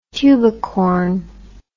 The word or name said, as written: tubicorn